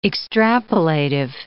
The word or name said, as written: extrapolative